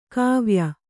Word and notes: ಗವಯ: gavaya

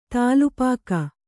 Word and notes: ತಲೆಪಾಗು: tale pāgu

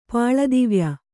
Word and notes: ಪಾಳದಿವ್ಯ: pāḷa divya

